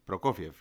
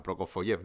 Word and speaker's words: Prokofiev: Ahora bien, cuando yo a mis 16 años empecé a tirar de ese hilito y de repente vi Prokofiev, yo lo pronunciaba mal Prokofoyev, no sabía ni quién era.